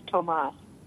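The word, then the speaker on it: Tomas: It's pronounced Thomas.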